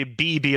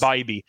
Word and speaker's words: Bibee: I think I used to say BB, and then I found out it was like Bibee, and I felt like an idiot because I said it wrong for the entire year. So I'm pretty sure it's Bibee.